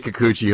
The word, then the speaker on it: Kikuchi: Yusei Kikuchi, hopefully I said that right.